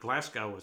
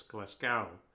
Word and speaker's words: Glasgow: It's not Glasgow, it's Glasgow, if I'm saying it right. Just like it's not Belfast, it's Belfast.